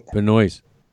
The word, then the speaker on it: Benoist: Melissa, is it Benoit or Benoit? – Benoist.